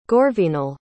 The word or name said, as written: gorvenal